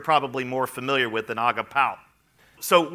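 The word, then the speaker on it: agapao (ἀγαπάω): The word Jesus used for love is agapao. Sometimes I call it agapeo, but it's actually pronounced agapao.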